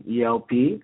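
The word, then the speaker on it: El-P: Killer Mike and ELP. I said it right this time, EL. I said EIP in the interview.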